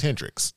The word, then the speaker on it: Hendrix: So, y'all know that my last name is Hendrix.